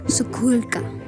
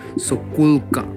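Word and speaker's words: Sokółka: Según esto, tengo entendido que se pronuncia Sokulka. Sokulka, Sokulka. Ok, ¿dónde está Sukulka? – Sukulka está.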